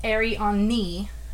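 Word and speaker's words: Arianni: I might be pronouncing that wrong, but it's A-R-I-A-N-N-I. – Ariane. – Okay. When I looked up, because I was like, how do you say that? So, I did look up on Google, and it did say Ariane.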